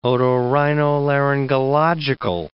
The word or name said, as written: otorhinolaryngological